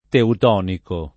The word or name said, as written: teutonico